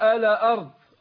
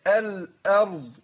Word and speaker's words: الأرض: فعلا كثير من الناس يقول إيه، هي طبعا يقول الأرض، الراء مفخمة، البعض بيرقق الراء أو ما ينطقش الضاد صح، يعني يقف على دال مش على ضاد. – أو يقلقل الأرض أقول ألا أرض، يحرك الإيه ألا كده، لا أقول الأرض يبقى برقق الهمزة وبفخم الراء والضاد، أو الأرض الأرض هكذا.